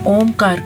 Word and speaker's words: ओम: ओमकार शब्द, ओम जो शब्द है वो हमारा 3 अक्षरों से मिलकर बना है, आ, उ और मा। तो इसको उच्चारण करने का सही तरीका मैं आज आपको बताऊंगी। आ का उच्चारण थोड़ा कम देर के लिए, ओ का उच्चारण उससे थोड़ा ज्यादा, और माँ का उच्चारण थोड़ा डीप करते हैं।